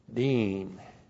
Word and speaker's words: deen: That's D long I-N, deen.